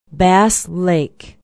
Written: bass lake

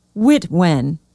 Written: witwen

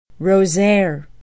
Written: rosiere

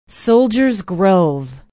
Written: soldiers grove

